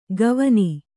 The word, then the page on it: ಗವನಿ: gavani; Kannada